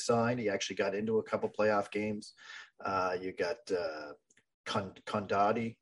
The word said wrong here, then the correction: Condotta: Is that how you say it? Condotta, they just signed as a free agent. Condotta. Yeah.